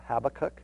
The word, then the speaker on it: Habakkuk: Next we have Habakkuk, or some pronounce it Habakkuk.